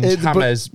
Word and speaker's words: James: It's like how James Rodriguez's name is James. It's not James. He's saying it wrong.